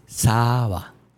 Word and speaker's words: Saba: Saba es servidor en japonés. Es Saba. – Así se pronuncia. – Saba. Correcto. Este, y es servidor en japonés.